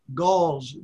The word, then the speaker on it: Gauls: Gauls, G-A-U-L-S. – G-A-U-L-S. Now, are they then the Ephesian people that became Christians, these Gauls? – No, Gauls were those who were always attacking Rome.